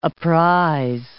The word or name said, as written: apprise